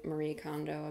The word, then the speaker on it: Marie Kondo: if you want to be Marie Kondo about it, that's how you say her name.